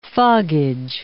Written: foggage

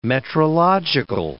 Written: metrological

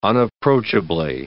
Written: unapproachably

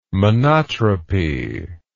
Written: monotropy

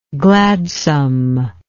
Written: gladsomeness

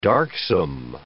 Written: darksome

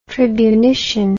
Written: tribunitian

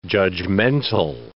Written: judgmental

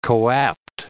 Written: coapt

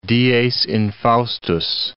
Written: dies infaustus

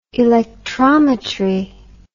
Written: electrometry

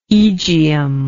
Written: aegium

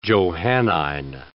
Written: Johannine

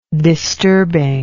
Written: disturbing

